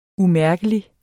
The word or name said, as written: umærkelig